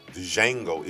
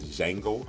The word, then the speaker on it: Django: Django is the wrong interpretation of it. It is Django Unchained, the D is silent, filmed by the great Quentin Tarantino.